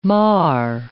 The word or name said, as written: mar